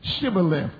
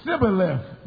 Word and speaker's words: shibboleth: They knew that the children of Ephraim couldn't pronounce the word shibboleth because they couldn't make that SH pronunciation with your tongue at the roof of your mouth. So they asked them, they said, say shibboleth. And whenever they tried to say shibboleth, they said, Sibboleth.